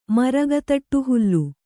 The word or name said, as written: ಮರಗತಟ್ಟುಹುಲ್ಲು